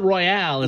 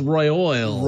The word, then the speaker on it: royal: It's royal. It's not royale. It's royal.